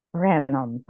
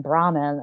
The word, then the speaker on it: Branham: I say Branham, but I don't know if I'm actually saying it right. It's like. – Branham, the, yeah, Branham, Branham. The, yeah, that's better. I keep calling him Brahmin, and I wish I would stop doing that.